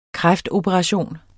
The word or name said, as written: kræftoperation